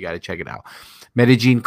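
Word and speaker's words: Medellín: By the way, Medellin is not Medellin. It's Medellin is how they pronounce it over there.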